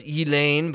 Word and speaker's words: Ghislaine: I think, and I don't believe we're being too extreme here, you could probably say, and I know it's Elaine, but it's spelled Ghislaine, and Ghislaine Maxwell, former pedophile, right?